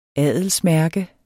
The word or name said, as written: adelsmærke